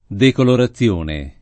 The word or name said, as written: decolorazione